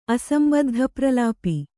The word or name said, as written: ಅಸಂಬದ್ಧಪ್ರಲಾಪಿ